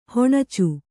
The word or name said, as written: ಹುಣಚಿ